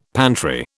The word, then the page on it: pantry: British English